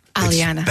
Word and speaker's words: Aliana: And not only stars Karen Rogers and Nitti Gahan, but the two ladies in here, Alicia Vitarelli and Elena. – Aliana. – That's okay. I know the name, but it's spelled so funky.